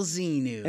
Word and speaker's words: Xenu: and this guy's name is General Xenu. – General Xenu. – And that's spelled X-E-N-U.